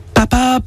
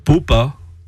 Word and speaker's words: papa: Papa peut se prononcer papa, popa ou poupa.